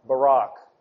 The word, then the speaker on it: Barak: And I was asked in the intermission concerning the pronunciation of Barak as opposed to Barak, and I would just say think in your mind of the former Prime Minister of Israel, Ehud Barak, as opposed to other names which may seem a little more distasteful.